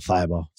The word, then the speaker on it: Thybulle: But uh but Thibault in general, Thibault, Thybulle. Sorry, pronunciation dyslexia.